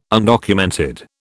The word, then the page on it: undocumented: British English